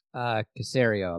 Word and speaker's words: Caserio: And so if you're the new general manager, is this Nick Caserio? Is that his name? – Caserio, I believe.